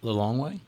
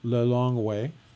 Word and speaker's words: Lilongwe: How do you pronounce the name of the capital? – Lilongwe. – Lilongwe.